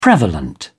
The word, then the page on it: prevalent: British English